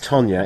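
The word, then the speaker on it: Toña: Actually, I've never told you this, but the reason T-O-N-A is pronounced Tonya, it has a cedilla over the end. – Is that a hat on the end? – Yeah, it's the hat, cedilla.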